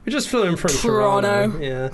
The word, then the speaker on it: Toronto: But if you're from Toronto, you have to say Toronto. You don't pronounce the T.